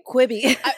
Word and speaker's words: Quibi: Quibi.